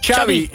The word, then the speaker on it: Xabi: Xabi like chocolate. – Yes, okay, thank you very much. – Wij deden het al goed. – Xabi, ja, Xabi.